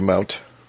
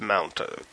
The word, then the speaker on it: mont: Is it Dragon Mount or Mount? – I think it's supposed to be Mount. – Okay, it's spelled Mont, but pronounced Mount.